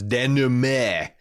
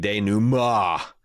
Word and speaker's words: denouement: concludes with, Aaron, I have also have a love cringe relationship with the way you pronounce denouement. Don't ever stop, but it's more properly like denouement.